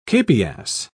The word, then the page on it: capias: British English